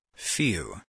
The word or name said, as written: few